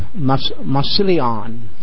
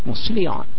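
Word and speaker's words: Massillon: We don't say the name right. It's Massilion or something like that. Massilion. Is that close? Pretty close.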